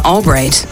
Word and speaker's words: Albright: Selena Albright.